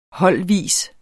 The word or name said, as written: holdvis